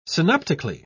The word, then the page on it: synoptically: British English